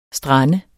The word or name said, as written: strand